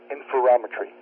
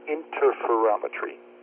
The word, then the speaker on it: interferometry: You got a slight mispronunciation there, John. You pronounced it interferometry. Most antenna people call it interferometry.